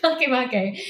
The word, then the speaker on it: Makemake: Makemake. – Right. – Which I honestly thought was pronounced make-make for an embarrassingly long amount of time until I was like 30 years old and 7 years as a professional astronomer.